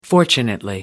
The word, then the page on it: fortunately: American English